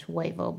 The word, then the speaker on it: huevo: I sort of knew 21 across, eggs, Spanish, huevo.